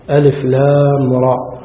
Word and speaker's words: الر: ألف لام راء، هكذا تنطق ألف لام راء،